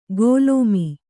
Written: ಗೋಲೋಮಿ

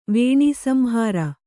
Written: ವೇಣೀಸಂಹಾರ

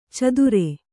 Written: ಚದುರೆ

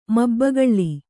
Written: ಮಬ್ಬಗಳ್ಳಿ

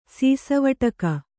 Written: ಸೀಸವಟಕ

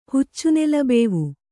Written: ಹುಚ್ಚುನೆಲಬೇವು

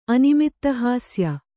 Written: ಅನಿಮಿತ್ತಹಾಸ್ಯ

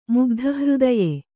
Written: ಮುಗ್ಧಹೃದಯ

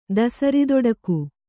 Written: ದಸರಿದೊಡಕು

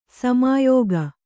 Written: ಶಮ್ಯಾಕ

